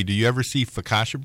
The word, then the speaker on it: focaccia: Focaccia bread. – Yes. I always thought I thought the O's were like long O's and that focaccia. It's just focaccia? – That's how I say it.